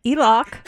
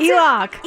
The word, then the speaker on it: Elok: We have Alok, spelled A-L-O-K. – Oh, I would like to correct you. It's Elok, and that is real DJ. – See, this is what I mean. You're better than you thought. So it's how do you pronounce Elok. – It? Elok.